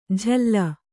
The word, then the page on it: ಝಲ್ಲ: kannada